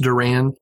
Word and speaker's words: Duran: Yeah, so I'm going to assume that his name is pronounced Duran, like the band, right?